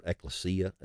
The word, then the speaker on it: ecclesia: The word there, and it's pronounced in various ways, and I'm not a Greek scholar, but it's the word ecclesia, as right. – we typically anglicized it.